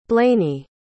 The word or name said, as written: blaney